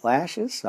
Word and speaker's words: Lachish: Then the army of the king of Babylon waged war against Jerusalem and against the cities of Judah and against Lachish, is that how you say it? Lachish, all right.